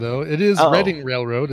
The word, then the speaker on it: Reading: It is Reading. It's in Reading, Pennsylvania.